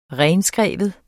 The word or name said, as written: renskrive